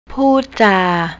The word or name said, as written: poodja